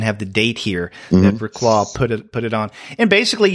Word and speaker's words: Reklaw: Jesse Reklaws. I'm not sure how you pronounce the name. R-E-K-L-A-W. Reklaw. Reklaw. – I go Reklaw.